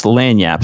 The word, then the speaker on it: lagniappe: It is pronounced Lanyap. So, it sounds like it would be spelled L-A-N-Y-A-P. But it is mm-hmm. spelled L-A-G-N-I-A-P-P-E.